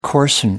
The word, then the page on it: coarsen: American English